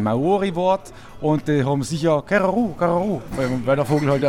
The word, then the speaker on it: Kereru: Bevor ich überhaupt irgendwas zum Bier frage, einmal die erste Frage: Wie spricht man es eigentlich richtig aus? Keraru wird es wohl nicht sein, oder? – Doch, genau so ist es. Keraru.